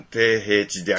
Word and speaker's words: 低平地: 低平地って読むかな、これ。 – 平地やろ、低平地である。 – 低平地です、うんうん。